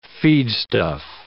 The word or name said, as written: feedstuff